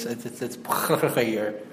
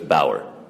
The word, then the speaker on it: Baauer: It's Boerger. Okay, bye. – Just say Bauer. – Sorry, I really didn't like butchering a name. I'm pretty sure the name is Bauer.